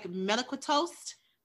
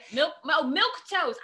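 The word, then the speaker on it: milquetoast: Let me know if I'm saying it wrong, but it looks like melequetoast. Am I saying it right? – Milk toast.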